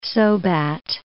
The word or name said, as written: Sobat